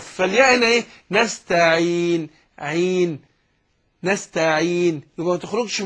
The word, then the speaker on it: نستعين: لا، اقول نستعين، نستعين، لكن ما قولش نستعين، إن لا، خطأ، يبقى نستعين بس.